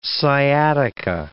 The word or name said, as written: sciatica